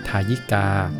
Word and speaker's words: ทายิกา: ทายิกา